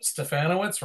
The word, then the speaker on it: Stefanowicz: Stefanowicz rhymes with Bananowicz, Rob.